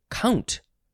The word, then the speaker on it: compt: C-O-M-P-T. It definitely is. You could say compt, or you could say count. Count? Yes, you can say count for this word spelled C-O-M-P-T.